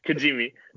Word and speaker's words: Kijimi: So they go to how do you pronounce it? Kimiji? – Kijimi. – Kijimi.